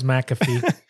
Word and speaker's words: McAfee: John McAfee. And some people were pronouncing it Mcafee, but I'm pretty sure it's McAfee.